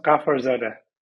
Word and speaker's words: Ghafarzade: It's pronounced Ghafarzade.